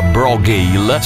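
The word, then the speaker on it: Brògeal: Si chiamano Brogale, si scrive Bro con la O accentata Geal, ma si pronuncia Brogale.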